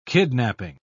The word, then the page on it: kidnaping: British English